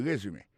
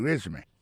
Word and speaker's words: résumé: It's called a résumé. Alors là encore, si on a parlé de accent, accent tombé sur le premier syllabe. It's called a résumé.